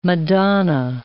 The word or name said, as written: Madonna